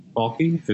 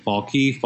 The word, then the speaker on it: Falchi: I don't particularly pronounce my last name any which way because the grandparents are very inconsistent with it. So it's like Falchi, Falchi. I don't care. They said it both ways, so I say it both ways.